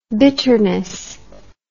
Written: bitterness

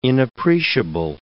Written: inappreciable

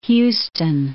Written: Houston

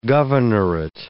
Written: governorate

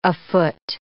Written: afoot

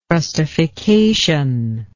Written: trustification

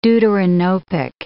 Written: deuteranopic

